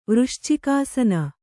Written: ವೃಶ್ಚಿಕಾಸನ

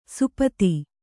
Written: ಸುಪತಿ

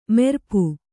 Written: ಮಾರಾಪು

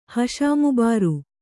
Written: ಹಷಾಮುಬಾರು

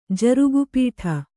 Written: ಜರುಗುಪೀಠ

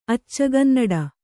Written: ಅಚ್ಚಗನ್ನಡ

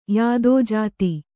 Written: ಯಾದೋಜಾತಿ

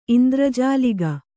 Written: ಇಂದ್ರಜಾಲಿಗ

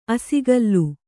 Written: ಅಸಿಗಲ್ಲು

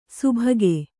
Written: ಸುಭಗೆ

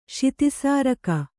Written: ಶಿತಿಸಾರಕ

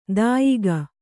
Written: ದಾಯಿಗ

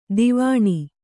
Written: ದಾವಣ

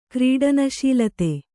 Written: ಕ್ರೀಡನಶೀಲತೆ

